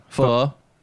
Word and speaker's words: pho: Do you eat pho? – I eat pho, yeah. Pho. Pho. You gotta say it right. Pho.